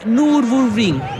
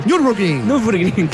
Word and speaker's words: Nürburgring: Corrió la Copa 2, como decía, la Cup 2, como se dice, decía Diego, y ganó las 24 horas de Nürburgring. Lo pronuncié muy mal. – Nürburgring, claro. Rapidito junto mejor.